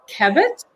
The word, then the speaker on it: Kabat: And today's guest is Leanne, and I hope I'm going to say your last name, Kabat.